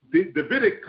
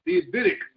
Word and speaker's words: Davidic: And not only did God was well pleased with David, but he said this, he established the David-Adamic covenant. And that means that David, everybody that's sitting upon the throne, the Davidic covenant, sorry, I said it wrong, the Davidic covenant, sorry, I said that wrong.